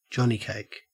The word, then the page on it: johnnycake: Australian English